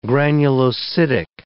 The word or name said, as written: granulocytic